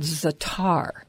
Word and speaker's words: za'atar: So, Jeff, I love something called za'atar, and it's spelled Z-A-A-T-A-R.